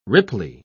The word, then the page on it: ripply: British English